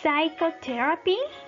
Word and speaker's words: psychotherapy: Silent P, Esta P no la vamos a pronunciar. Psychotherapy, psychotherapy, psicoterapia, psychotic, psicótico, y raspberry, raspberry, frambuesa.